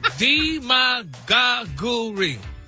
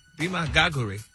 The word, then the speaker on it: demagoguery: G-U-H-R-E. Demagoguery. – That's the way the dictionary broke it down. – The pronunciation, like the emphasis is on the D.